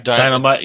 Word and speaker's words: Dynamutt: So, you know, the stories, okay, you know, like you said, with Blue Falcon and Dynamutt, it's like, is that his name? Did I get the name right? – Dynamutt, yes.